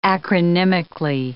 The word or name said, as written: acronymically